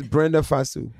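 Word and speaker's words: Brenda Fassie: It's Brenda Fasu.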